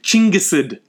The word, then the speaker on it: Chinggis: We also need Chinggisid legitimacy. Chinggisid goes the original Mongolian name of Genghis Khan. Genghis is the Persian pronunciation of Chinggis.